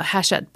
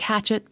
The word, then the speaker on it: Hachette: If you go to the Hachette website, and that's Hachette Books, it will be linked in the show notes because it's spelled like it looks like hatchet, but it's Hachette because it's fancy.